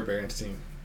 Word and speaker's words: Berenstain: I thought it was Berenstain. – It's for sure Berenstain.